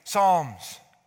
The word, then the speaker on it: Psalms: Yes, the P is silent. Psalms.